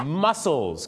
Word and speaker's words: mussels: mussels ครับที่แปลว่ากล้ามเนื้อเหรอครับที่จริงออกเสียงเหมือนกันแต่สะกดกันไม่เหมือนกันนะครับ